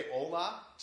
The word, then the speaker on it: hola: in my limited Spanish understanding, the H is silent. So let's say hola to everyone down there.